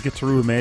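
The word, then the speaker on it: Gitaroo Man: It's choreographed so well because in Gitaru Man, all the it actually is pronounced Gitaru Man, it's, even though it's weird as it sounds,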